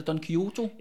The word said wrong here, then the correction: Don Quixote: Jeg tror, det er Don Quixote eller Don Quixote. Don Quixote.